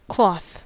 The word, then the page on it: cloth: American English